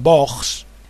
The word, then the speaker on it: Boggs: Ya sabéis que fue el conductor que llevó a aquel miembro de la comisión Warren llamado Boggs, apellidado Boggs, os lo deletreo, para que no lo confundáis con los nazis españoles. B-O-G-G-S. Así se pronuncia.